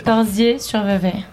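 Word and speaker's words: Corsier-sur-Vevey: Le 12 septembre, est-ce que je prononce bien, vous serez à Corsières-sur-Vevey. – Corsiers-sur-Vevey. – C'est juste Corsiers-sur-Vevey en Suisse.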